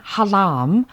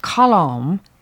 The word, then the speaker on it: halam: Music played on the five-string halam, or as sometimes pronounced, khalom, the lovely Senegalese lute played by professional folk musicians.